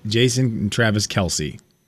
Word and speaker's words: Kelce: and everybody's been calling him Kelce, and it is spelled K-E-L-C-E. – Right. – Everyone's called us Kelce for so long that we just haven't really corrected it.